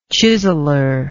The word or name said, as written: chiseler